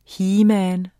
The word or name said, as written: heman